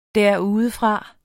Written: derudefra

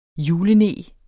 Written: juleneg

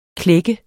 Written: klække

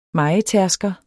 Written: mejer

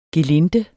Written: gelinde

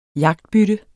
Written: jagtbytte

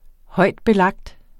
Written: højtbelagt

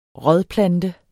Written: rådplante

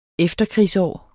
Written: efterkrigsår

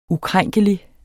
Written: ukrænkelig

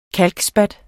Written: KALKSPAT

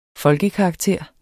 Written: folkekarakter

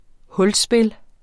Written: hulspil

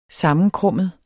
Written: sammenkrummet

